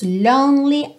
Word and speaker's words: lonely: Então, aqui também no lonely, cuidado para você não falar lonely, lonely. Esse é do meio não é pronunciado. Então, pensa lone, lone. Pensa terminando esse som no N, tá? Lonely, lonely, lonely.